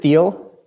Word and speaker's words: Thiel: And you were actually awarded the prestigious Teal Fellowship, right? It's T-H-I-E-L. How do you pronounce that? – I pronounce it Thiel with the T-H.